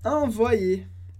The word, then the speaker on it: envoyer: Mas esse Y a gente pode pensar como dois Is separados. Então, para a pronúncia envoyer, a gente tem esse som YE, porque o Y a gente pode pensar em vários casos como dois Is separados, que dá o som envoyer.